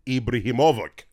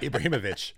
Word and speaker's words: Ibrahimovic: And finally, recently retired, not short of confidence, Zlatan Ibrahimovic. – He went to... – I definitely mispronounced that. – Ibrahimovic.